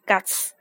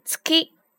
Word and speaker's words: 月: 毎日，每天，毎日，每天早上，毎朝毎朝每天晚上毎晩毎晩每个星期毎週毎週每个月毎月毎月注意这个月咱们在这个地方读的不是 guts guts 读的是 tsuki tsuki